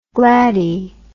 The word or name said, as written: gladdie